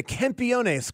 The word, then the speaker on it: Campeones: How do you say it again? Campeones. – Campeones. – Campeones. – Campeones. Yeah, he'll help us with our Spanish. – Yeah, Campeones.